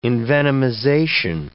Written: envenomization

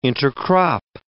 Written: intercrop

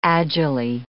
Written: agilely

